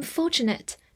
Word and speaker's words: fortunate: N A T E，fortunate，前面 F O R 发 for，T U 的发音比较特殊，发 ch，N A T E 发 nit，fortunate，fortunate，这是一个形容词，表示幸运的、侥幸的。举个例子，I'm fortunate to have a good teacher，我很幸运有位好老师。